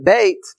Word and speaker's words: Beit: the Bet is enlarged. And so Bet, or you can pronounce it Beit in Hebrew, means a house.